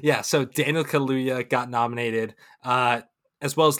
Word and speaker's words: Kaluuya: How do we pronounce it? Because I'm not sure. Is it Daniel Kaluuya or Daniel Kaluuya? – Kaluuya. – Okay. Kaluuya.